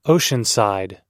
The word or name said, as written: oceanside